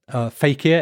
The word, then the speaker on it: fakir: Fakir, F-A-K-I-R, fakir.